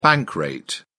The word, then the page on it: bank rate: British English